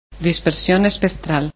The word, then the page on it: spreadsp: Spanish